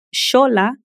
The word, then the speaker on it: Xola: Por exemplo, a palavra xola se escreve X-O-L-A.